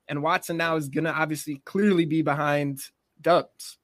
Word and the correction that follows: Doubs: Doubs. We learned how to say his name, Doubs.